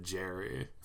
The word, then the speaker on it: Gary: His name's Gary, but he pronounces it Jerry. – I missed that. – His name is Jerry, but it's spelled G-A-R-Y.